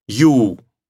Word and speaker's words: you: You. Чуть-чуть нужно тянуть. Конечно, если скажете коротко, тоже не страшно, но старайтесь уже говорить красиво. You.